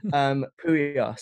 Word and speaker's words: Puya: Puya, spelled P-U-Y-A-R.